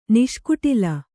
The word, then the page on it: ನಿಷ್ಕುಟಿಲ: niṣkuṭila; Kannada